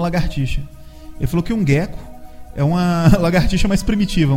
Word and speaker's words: gecko: É, aí é o seguinte, talvez tenha a ver com gecko, porque é gecko, né? Gecko mesmo, a pronúncia é essa.